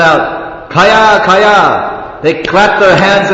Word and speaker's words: Kaya: Kaya? K-A-Y long A. Kaya.